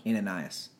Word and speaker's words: Ananias: Ananias. – Thank you. I like totally lost his name. I'm so proud of you for saying that correctly.